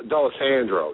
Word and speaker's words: D'Alessandro: D'Alessandro, you know.